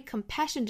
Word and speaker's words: compassion: 那我们注意 compassion 这个单词呢，中间的这个两个 s 要读成一个清辅音 sh, compassion. 那一般来说以 s-i-o-n 后缀的名词呢，那如果说前面是两个双写的这个 s, 一般它要读成一个清辅音 sh, shen, compassion, 不要读成了忍啊，是 compassion.